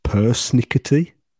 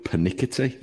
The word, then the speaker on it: persnickety: It's persnickety. It's how it's spelled. P-E-R-S-N-I-C-K-E-T-Y, and it's placing too much emphasis on trivial or minor details or fussy. – Well, I got the definition right, but I got the pronunciation wrong. I thought you were pernickety.